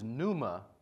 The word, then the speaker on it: pneuma: And it's the Greek word pneuma, and it's spelled P-N-E-U-M-A, but the P is silent, pneuma. It always refers to spirit.